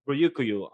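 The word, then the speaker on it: Ryokugyu: Ryukyu. Am I pronouncing the name right? – I believe so.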